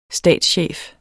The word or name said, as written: statschef